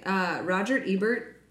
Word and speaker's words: Roger Ebert: Roger Ebert, is that how you say it? – Mm-hmm.